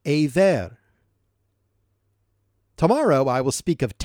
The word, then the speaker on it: Eber: Also to Shem, the father of all the children of Aver, and the older brother of Japheth, children were born. That name there, Aver, in English is spelled E-B-E-R, and it is thought by some to be the origin of the word Hebrew.